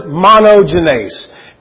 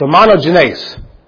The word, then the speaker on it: monogenes: That word is monogenes. M-O-N-O-G-E-N-E-S. And I'm pretty sure I'm pronouncing that correctly, because one great thing about the Blue Letter Bible is they have a guy that says it.